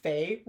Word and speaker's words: Feyre: Is her name Feyre? That's how the Bone Crusher Carver pronounced it. I'm shocked. – Because you were saying fair up to that point.